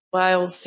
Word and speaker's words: Lyle: I looked up this pronunciation and I saw many different pronunciations, so I hope it's Lyle. – Lyle's, yep.